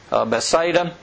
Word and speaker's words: Bethsaida: There's several pronunciations. I'll just take the easy one like I always do. Bethsaida. And every time that the water stirred up by an angel, whoever could get in the water first was healed.